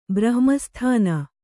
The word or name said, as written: ಬ್ರಹ್ಮಸ್ಥಾನ